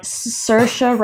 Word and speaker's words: Saoirse: Saoirse rhymes with inertia.